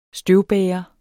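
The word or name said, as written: støvbærer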